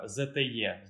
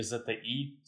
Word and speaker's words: ZTE: Кстати, под удар попала тогда еще фирма ZTE, знаешь такую? Или ZTI, скорее правильно произносить.